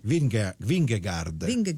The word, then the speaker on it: Vingegaard: Vingegaard.